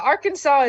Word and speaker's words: Arkansas: Yeah, Arkansas is in the house. – Oh, we got that state that's named after Kansas, but they don't pronounce their name like Kansas.